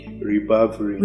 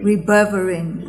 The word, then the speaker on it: ribavirin: Ribavirin. – Ribavirin.